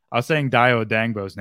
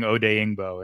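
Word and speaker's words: Odenigbo: Because I was saying Dayo Odangbo's name wrong for, like, the whole year, his whole rookie year. I was saying Odeyingbo, and it's Odangbo.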